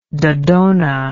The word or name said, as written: dodonean